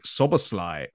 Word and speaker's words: Szoboszlai: And Dominic Szoboszlai from RB Leipzig. That is how you pronounce his name, according to my Hungarian girlfriend.